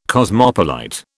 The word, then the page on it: cosmopolite: British English